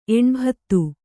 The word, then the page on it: ಎಣ್ಭತ್ತು: eṇbhattu